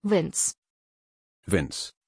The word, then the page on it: vinz: German